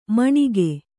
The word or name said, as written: ಮಣಗು